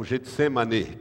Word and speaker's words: Getsemane: A palavra Getsemane ela, o Getsemane, que é a pronúncia certa com E no fim, Getsemane significa lagar de azeite.